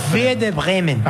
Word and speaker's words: Werder Bremen: Que va a decir mejor, que le sale la pronunciación. – Werder Bremen. – Ahí está.